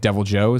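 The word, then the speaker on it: Deviljho: Gary, and he was like Devil Joe is how he pronounced it.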